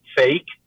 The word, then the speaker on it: PHEIC: And they can declare a public health emergency of international concern, PHEIC, pronounced fake.